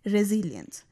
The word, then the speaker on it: resilience: इसकी जो स्पेलिंग है वो है RESILIENCE, और इसको बोलते हैं रेजिलियंस।